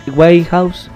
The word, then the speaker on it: Winehouse: Winehouse, ahí lo digo bien el nombre.